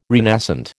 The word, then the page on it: renascent: American English